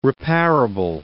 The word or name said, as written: repairable